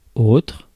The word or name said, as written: autre